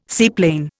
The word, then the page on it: seaplane: Indian